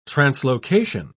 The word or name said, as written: translocation